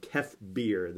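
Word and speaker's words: Kef Bir: ocean world of Kefbir. This is apparently how it's pronounced.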